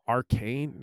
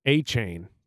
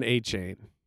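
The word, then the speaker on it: Achane: Oh, Devon Arcane? No, it's not Arcane, though. A-Chain. That's how he'd pronounce it. Devon A-Chain.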